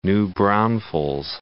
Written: New Braunfels

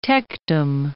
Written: tectum

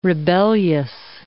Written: rebellious

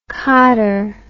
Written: codder

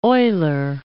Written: Euler

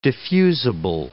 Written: diffusible

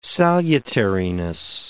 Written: salutariness

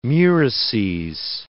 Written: murices

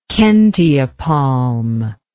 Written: kentia palm

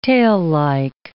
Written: taillike

